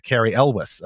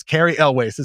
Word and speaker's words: Cary Elwes: So I wanted to go and confirm it's Cary Elwes is how you say that.